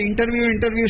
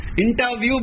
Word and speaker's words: इंटरव्यू: इंटरव्यू शब्द बहुत प्रचलित है, परंतु इंटरव्यू शब्द का मतलब ही नहीं मालूम है, उसको इंट्रू बोलते हैं लोग। क्या क्या बोलते हैं उसको, इंटरव्यू, या उसका बराबर उच्चारण भी नहीं कर पाते हैं लोग। इंटरव्यू बोलना चाहिए, परंतु कोई एक व्यक्ति भी इंटरव्यू नहीं बोलता है।